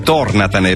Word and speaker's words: tornatene: Ha cancellato la parola tornante, ne ha messa un'altra dove c'è scritto tornatene, anzi tornatene, perché l'accento va sulla prima O e dà il senso giusto, per cui è un'installazione provocatoria in realtà per dare voce al territorio e riaccendere il dibattito sull'overtourism di cui spesso parliamo.